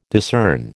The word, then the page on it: discern: American English